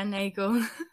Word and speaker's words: Nagel: Yeah, Nagel.